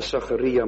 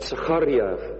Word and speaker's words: Zacharia: Even tussendoor, we hebben geleerd meestal om te zeggen Zacharia, maar eigenlijk moet je zeggen Zacharia. Dat klinkt trouwens ook mooier, vind ik. Zo wordt het door het Joodse volk uitgesproken, de woorden van Zacharia.